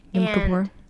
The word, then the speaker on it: Yom Kippur: Yom Kippur.